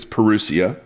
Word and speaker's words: parousia: Now, that word is a form of the word parousia or parousia, but I think it's parousia. It's how you pronounce it.